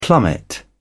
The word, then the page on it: plummet: British English